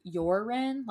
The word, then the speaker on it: Joran: It's spelled Joran, but I heard it pronounced Joran, like with a Y.